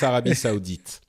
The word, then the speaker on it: Arabie Saoudite: In French, it's Arabie Saoudite.